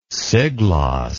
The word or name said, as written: siglos